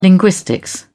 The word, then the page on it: linguistics: British English